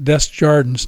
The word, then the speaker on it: Des Jardins: Uh, only they pronounce it Des Jardins down there in the valley.